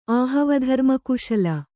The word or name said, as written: ಆಹವಧರ್ಮಕುಶಲ